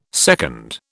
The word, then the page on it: seconde: British English